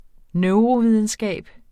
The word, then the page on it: neurovidenskab: [ ˈnœwʁo- ]; [ ˈnεwʁo- ]